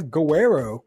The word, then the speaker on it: Gowero: I'm here today with Klaudia Gowero.